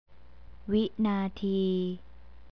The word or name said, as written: winatee